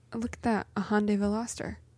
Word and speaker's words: Veloster: Mom tried to tell Ivy that it was a velociter. – Oh yeah, we had to argue. – Oh, she added in an extra syllable. Mm-hmm. – Yeah, and I was like, no, you're wrong. You're wrong. That's not what it's called. It's a Hyundai Veloster.